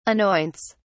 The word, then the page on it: anoints: British English